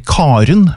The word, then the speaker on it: Karen: eller Karen, hvis du uttaler æren tydelig.